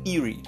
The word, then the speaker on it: eerie: E a pronúncia é essa, tá? Os dois S ele tem a pronúncia de i e o R como é o R na língua inglesa, com R, ok? Eerie, eerie.